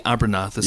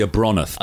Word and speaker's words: Abronath: And of course, Kral is he's in search of the MacGuffin, the big weapon, the Abernath. Again, we've only seen it once. So it's called the Abronath, but everyone else called it Abernath. I'm like, it's Abronath, for God's sake. Say it right.